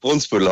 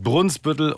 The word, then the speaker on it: Brunsbüttel: Ich habe immer Brunsbüttel gesagt. Immer. – Ja, richtig. Brunsbüttel heißt das. Wird in den Medien auch falsch gesagt. – Brunsbüttel. Ne? Also wir merken uns das für alle Zeiten. Es heißt Brunsbüttel und nicht Brunsbüttel. So, richtig.